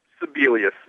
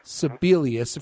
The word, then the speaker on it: Sebelius: Is that how you say her name? – Sebelius, yeah. – Sebelius. Of course, you guys affectionately call her the abortion clinic cockroach queen.